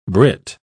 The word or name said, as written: brit